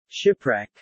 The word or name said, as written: shipwreck